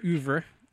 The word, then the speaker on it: oeuvre: I am familiar with your oeuvre. – Yes, please.